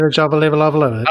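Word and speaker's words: Milla Jovovich: So, first of all, Mila Jovovich. I think that's how you pronounce it. – Mila Jovovich.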